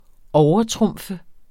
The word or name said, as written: overtrumfe